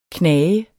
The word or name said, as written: knage